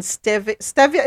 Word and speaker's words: stevia: E-R-Y-T-H-R-I-T-O-L. It is used to add bulk or sweeten stevia. Is it stevia or stevia? What's the right pronunciation? – Stevia. – Stevia. Thank you.